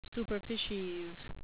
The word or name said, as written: superficies